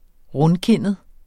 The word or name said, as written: rundkindet